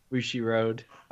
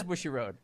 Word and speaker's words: Bushiroad: It, how do you, how do you pronounce it? Bushiroad. Oh, it is Bushiroad.